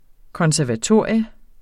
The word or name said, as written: konservatorium